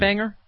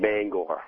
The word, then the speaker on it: Bangor: It's the way people pronounce it, because they don't know how to pronounce it. It's actually pronounced Bangor. – Bangor. Okay. – Yeah, pardon me. – It's not, but because we're on the East Coast and we have an East Coast accent, it's called Banger.